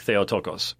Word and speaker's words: Theotokos: or as it's pronounced in modern Greek, Theotokos, which, by the way, I was discussing Theotokos.